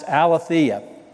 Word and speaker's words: aletheia: In fact, this word that we find in 3 John is a Greek word, and it's pronounced aletheia.